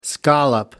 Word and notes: scallop: American English

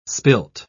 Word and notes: spilt: British English

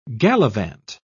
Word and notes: galavant: British English

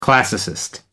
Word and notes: classicist: American English

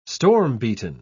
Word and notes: storm-beaten: British English

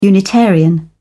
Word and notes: unitarian: British English

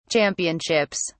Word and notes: championships: British English

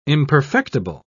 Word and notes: imperfectible: British English

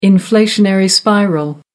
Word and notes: inflationary spiral: American English

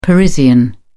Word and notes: parisian: British English

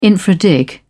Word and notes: infra dig: British English

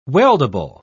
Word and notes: weldable: British English

